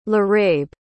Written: laraib

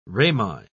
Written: Rami

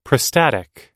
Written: prostatic

